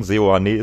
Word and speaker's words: Seoane: Wir haben auch noch nicht darüber gesprochen, dass Gerardo Seoane, und das müssen wir leider berichtigen, Seoane ist die richtige Aussprache, nicht wie wir das in Folge 16 versucht haben ja, zu artikulieren, wie man den Namen ausspricht.